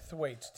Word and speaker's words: Thwaites: How do you say his last name? Thwaites. Thwaites.